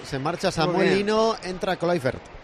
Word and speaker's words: Kluivert: Aceptamos Kluivert. – Todo. – Kluivert.